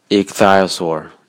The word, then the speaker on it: ichthyosaur: 而那些完全符合拼读规则的单词，其实百分之九十六以上的英文单词基本上符合拼读规则。就基本上无需标注音标了，比如 ichthyosaur 这个单词，ch 的读音是 k，y-u-o 的读音是 u，a-u-r 的读音是 or，重音在第一个音节。